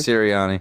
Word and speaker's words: Sirianni: Sirianni? Is that how you say his name? – Yeah, Nick Sirianni.